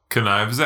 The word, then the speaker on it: knives: Today, we're talking about Knives Out. – Knives Out. – It's just knives. – I'm sorry, there is a silent K.